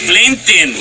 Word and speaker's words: LinkedIn: LinkedIn.